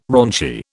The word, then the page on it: raunchy: British English